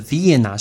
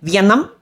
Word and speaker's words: Vienna: 奥地利的首都是 Vienna，是维也纳。你也不要念 Vietnam。